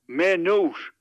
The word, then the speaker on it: Maynooth: Maynooth. – Right. – It rhymes with Beirut.